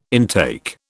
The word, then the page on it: intake: British English